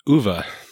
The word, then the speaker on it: Ove: And if you're looking for that book, I'll link it in the show notes, but it is, Uwe is O-V-E, which most people pronounce Ove. I happened to listen to the book, so I got the privilege of hearing it pronounced Uwe.